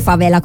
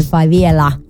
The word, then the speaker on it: favela: perché non dice favela come diremmo noi altri italiani, lei essendo mezza brasiliana dice favela.